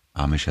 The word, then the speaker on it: Amish: Du bist bei Wien echt, bist du der emisch experte Amish heißt das. – Ich habe es amerikanisch ausgesprochen.